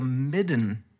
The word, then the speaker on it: midden: It's called a midden, an octopus's midden. – Oh. How do you spell that? M-I-D-D-E-N? – M-I-D-D-N. Midden. It's a fun word to say, a midden.